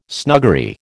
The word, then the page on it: snuggery: American English